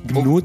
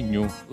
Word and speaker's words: GNU: GNU, detto anche GNU. – A seconda di come preferite. Dovrebbe essere GNU. Se qua ci fosse Elena, sicuramente ci farebbe notare. – Non si dice così.